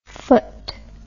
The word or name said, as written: pht